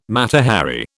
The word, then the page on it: Mata Hari: British English